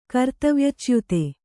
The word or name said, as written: ಕರ್ತವ್ಯಚ್ಯುತಿ